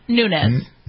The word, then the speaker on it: Nunes: Nunes.